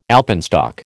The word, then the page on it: alpenstock: American English